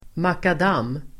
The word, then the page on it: makadam: [makad'am:]